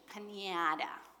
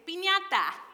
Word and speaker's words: piñata: As you say in the English language, piñata. Or if you say it correctly, it's piñata.